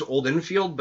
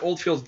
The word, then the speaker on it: Oldfield: I always thought it was Oldenfield, but then I realized I was fucking saying it wrong.